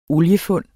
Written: OLIEFUND

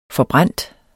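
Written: forbrændt

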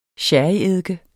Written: sherryeddike

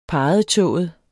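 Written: parrettået

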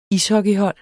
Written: ishockeyhold

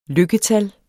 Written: lykketal

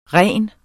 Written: ren

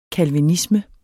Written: calvinisme

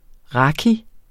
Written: raki

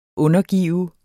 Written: undergive

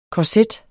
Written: korset

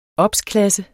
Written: obsklasse

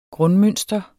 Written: grundmønster

